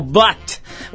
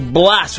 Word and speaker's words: Blat: E é o seguinte, hoje a gente está recebendo uma figura mais que especial, é o ator Caio Blatt ou Blas, se você preferir, ou Blatt, enfim, como você quiser pronunciar.